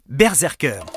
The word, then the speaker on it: BRZRKR: La BD s'appelle BRZRKR, 26 points au Scrabble, j'ai compté, et je me suis renseigné, si vous voulez prononcer le nom de la BD comme il se doit, eh bien on dit Berserker.